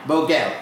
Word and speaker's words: Boegel: And I'm going to butcher your last name, but it's Boegel, right? – Boegel. – Yep. That's better.